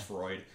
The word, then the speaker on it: Freud: I fucking read his name so weird because it's spelled Freud. – Yeah, it's Freud.